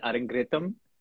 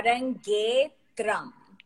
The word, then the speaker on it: Arangetram: Because I always end up pronouncing it as Arangritam. And I think it's the ending of it. Arangritam, right? – Yeah. Arangetram.